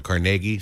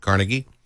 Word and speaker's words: Carnegie: Some call it Carnegie, some pronounce it Carnegie.